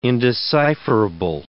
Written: indecipherable

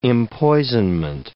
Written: empoisonment

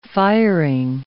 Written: firing

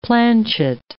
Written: planchet

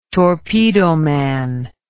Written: torpedoman

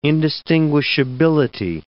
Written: indistinguishability

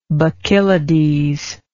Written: Bacchylides

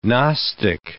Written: gnostic

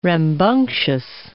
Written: rambunctious

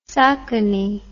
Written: Socony

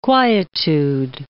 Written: quietude

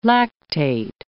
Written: lactate

